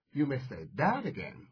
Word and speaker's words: you may say that again: 它发音的重点就是把重点放到 that 这个单词上，叫 you may say that again。